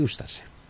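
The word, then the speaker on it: Ustaše: Και το υπόλοιπο που έχουμε αφήσει είναι η ούστασε. Βρήκαμε πως προφέρεται, τέλος πάντων. Καλά το λέω, ούστασε. – Ούστασε. – Τώρα δεν μπορώ να αυτά τα σίγματα παχιά δεν τα μπορώ.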